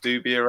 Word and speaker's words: Dubia: Is it Dubia roaches? Is that how you say it? – Yeah.